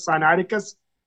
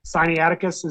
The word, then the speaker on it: Sinaiticus: So, not, I mean, other than Sinaitic, Sinaitic, I think I'm supposed to pronounce it Sinaiticus, but I've always, Sinaiticus is. – Yeah, that's how I always how I, hear it.